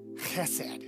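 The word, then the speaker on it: chesed (חסד): Okay, I'm butchering it, but it's chesed. It's spelled with an H, but it's like a chesed.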